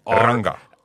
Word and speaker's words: Rangga: And forgive my pronunciation, it's called Rangga, I believe. – Rangga. – R-A-N-G-G-A.